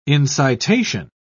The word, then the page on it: incitation: British English